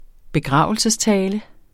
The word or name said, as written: begravelsestale